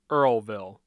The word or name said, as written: Earlville